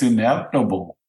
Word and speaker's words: inevitable: I think it's inevitable. – Ineptible? – Inevitable. – Inevitable. Sorry, you lost a syllable there.